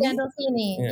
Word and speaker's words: Gandolfini: I think about Emilio Estevez on the Mighty Ducks now, and of course, you working with James Gandolfino and have I said it right? Gandolfini? – Gandolfini. Yeah, you almost Gandolfini. Had. I knew it was wrong as soon as I said it.